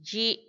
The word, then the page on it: yi: Macuiltianguis